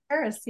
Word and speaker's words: Charis: Charis. Yeah, good job. – Okay, just making sure. I'm sure people really emphasize the CH, huh? – Oh, yeah.